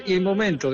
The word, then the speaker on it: il momento: En italiano se dice il momento, que es como se escribe, ¿no?